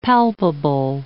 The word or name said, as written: palpable